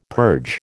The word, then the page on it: purge: American English